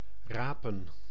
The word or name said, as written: rapen